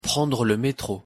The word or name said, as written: prendre le metro